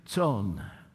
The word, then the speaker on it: Tson: Joseph Tone, spelled T-O-N, pronounced T-S-O-N, Tone.